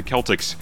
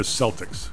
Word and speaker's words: Celtics: And the Suns lost to the Celtics, ending a game 125. – By the way, those are the Celtics. – Celtics, I knew it. I knew I was saying it wrong.